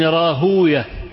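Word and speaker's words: راهويه: إسحاق ابن راهوية، المحدثون هكذا إيه ينطقونها، أما أهل اللغة فيقولون إسحاق ابن راهوي.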